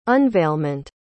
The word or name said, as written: unveilment